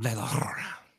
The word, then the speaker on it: La Llorona: Curse of La Llorona, and I don't even know if I'm ever saying it right. – The Curse of La Llorona.